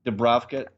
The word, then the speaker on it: Dubravka: That basically knocks out Lascelles, Lejeune, Dubravka. How do you say his name? Dubravka, yeah. – Dubravka, he's knocks him out.